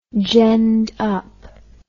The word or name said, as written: genned up